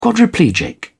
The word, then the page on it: quadriplegic: British English